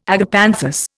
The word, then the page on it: agapanthus: Indian